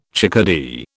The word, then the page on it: chickadee: British English